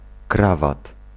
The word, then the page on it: krawat: [ˈkravat]